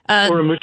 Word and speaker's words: Urumqi: And Gordon Chang, back to that fire that broke out in Urumqi, am I saying it correctly? – Urumqi, yeah.